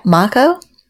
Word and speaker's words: Maiko: How do you pronounce it? M-A-I-K-O. Mako? – Maiko, yeah.